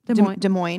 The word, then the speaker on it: Des Moines: I never know how to say it. Des Moines? – Des Moines.